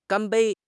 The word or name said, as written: gan1 bei1